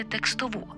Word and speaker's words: текстову: Наполегливо рекомендую опрацювати текстову. Так, пам'ятаймо й про правильний наголос. Текстову версію цього випуску подкасту «Ошатна українська» зі Світланою Білою на Громадському радіо.